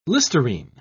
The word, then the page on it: Listerine: British English